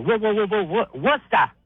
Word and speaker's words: Worcester: Worcester. Two syllables. Worcester.